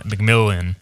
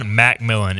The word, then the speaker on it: McMillian: If Savon Revel and Jaquan McMillian, apparently it's Jaquan, by the way. Jaquan McMillan is the official pronunciation.